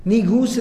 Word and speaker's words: Negus: Negus is the most appropriate pronunciation of it. It's the proper pronunciation of it, and that was Negus. Spelled N-E-G-U-S, Negus, An-Najashi in Arabic, or even in that African dialect, it was called An-Najashi, Najashi.